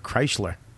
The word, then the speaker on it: Kreischer: And that's how you say it. I used to call you Bert Kreischer.